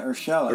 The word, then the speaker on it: Urshela: Yeah, Urshela.